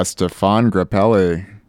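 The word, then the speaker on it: Stéphane Grappelli: Oh, that would be Stefan Grappelli.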